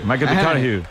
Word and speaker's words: McConaughey: Que hay que ir corrigiendo la pronunciación. McConaughey.